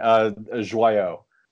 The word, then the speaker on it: Joyaux: Sorry, that isn't correct. How do I say your name correctly? – That's all right. Joyaux.